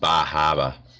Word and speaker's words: Bar Harbor: Well, up in Bar Harbor, you pronounce all of them except for the R's. – Oh, no, you pronounce the R's, just not where they're written.